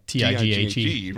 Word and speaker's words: Tighe: And, and his Twitter handle is the GH is silent. – Yeah, because his last name is, is spelled T I G H E.